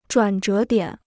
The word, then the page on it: 转折点: zhuǎn zhé diǎn